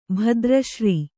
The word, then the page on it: ಭದ್ರಶ್ರೀ: bhadra śrī